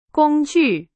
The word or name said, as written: 工具